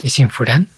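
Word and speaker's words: isshin furan: Tú me corriges cuando no lo pronuncie bien. – Bien, Ishinfuran, está bien.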